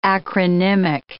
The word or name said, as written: acronymic